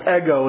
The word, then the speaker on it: ego: It's pronounced ego in Greek.